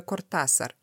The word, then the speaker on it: Кортасар: Ты специально для этого эпизода прочитала Ева Луна, а я э, перечитала Хулио Кортасара и поняла, что я всю жизнь неправильно произносила его фамилию. Я его про- фамилию произносила Кортасар, и сегодня прочитала в одном из источников, что его ошибочно фамилию произносит Кортасар, потому что он долгое время жил во Франции. И французы на свой манер, они же ставят ударение на последний слог, они его называли Хулио Кортасар. А его на аргентинский манер, оказывается, надо называть Хулио Кортасар. И он сам свою фамилию назвал так, Кортасар.